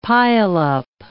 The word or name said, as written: pileup